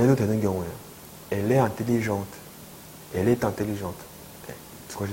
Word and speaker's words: intelligente: Elle est intelligente. Intelligente. 약간 트 발음이 나죠? 그 차이예요.